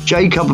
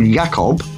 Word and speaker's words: Jakob: Jacob Mind is probably really called Jakob rather than Jacob, being Swedish, and Mind should probably be pronounced Mind. He should be Jakob Mind, except the Mind is not his surname.